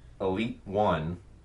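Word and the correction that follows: Elita One: You just hurt my soul because you mispronounced one of their names. – What? – Go ahead. – Which one? – It's Alita 1.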